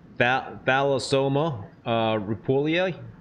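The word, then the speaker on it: Thalassoma rueppellii: The okay, Thalassoma rupulia. – Yes. – Did I get close yes. to pronouncing that correctly? – Yes.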